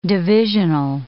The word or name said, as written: divisional